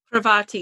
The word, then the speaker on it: Parvati: Parvati, yeah.